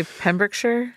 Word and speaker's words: Pembrokeshire: So he picked a county in the southwest of Wales, a place called Pembrokeshire. I may be pronouncing that incorrectly. – Probably Pembrokeshire.